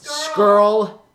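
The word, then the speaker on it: Scurll: I know that your friend Marty Skrull is also in the match. Did I just butcher his last name like I always do? – It's Skrull.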